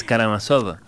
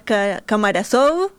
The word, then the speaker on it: Karamazov: Las, además, sí cambian sílabas. – Las Karamazov, ¿no? ¿Cómo? – Las... ¿Sí? Las Kamarazov, mucho. Las Kamarazón, un montón.